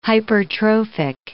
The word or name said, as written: hypertrophic